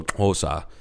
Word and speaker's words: Xhosa: It's XH is XHOSA is how that the name of the tribe and the language is what it's called is that's how it's spelled, and XH is how you express anglophonically is how you express the click going into the vowel sound, so Hosa. So okay. – Click into the O sound.